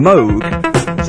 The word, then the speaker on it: Moog: In 1934, Robert Moog of synthesizer fame was born, and then told everybody to pronounce his name Moog.